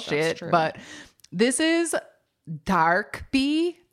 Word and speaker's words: DKB: But it's DKB is how it is just spelled. – Yeah. And it says on their wiki that it's pronounced Darkbi, and it's short for dark brown eyes.